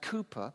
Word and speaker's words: Cowper: William Cooper, spelt Cowper, actually, C-O-W-P-E-R, but pronounced Cooper.